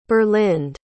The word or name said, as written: berlinde